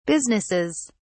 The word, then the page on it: businesses: British English